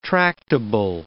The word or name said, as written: tractable